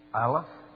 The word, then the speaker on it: Aleph: It's a consonant, but a silent one. And people have said who teach this language, you want to learn how to pronounce Aleph? Go, like you're going to say A. But don't say it. Everybody here now get ready to say, somebody coughed. Say it again. No, I heard you. You don't make a sound. You get ready to make a sound, but you don't make one.